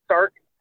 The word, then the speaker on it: Starks: You can find me on Twitter under Becca, B-E-K-A-H, Boodles, B-O-O-D-L-E-S. That's my handle on Twitter. And I'm also on Facebook under my name, which is Rebecca, spelled the same way, R-E-B-E-K-A-H, Stark, S-T-A-R-K-S.